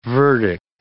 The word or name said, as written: verdict